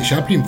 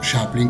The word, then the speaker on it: Chaplin: Y también gracias a la profesora Liliana Gaia, que dice que Chaplin fue británico y por lo tanto hay que pronunciarlo no Chaplin, como acabo de hacerlo ya dos veces recién, sino Chaplin, con la CH como chocolate.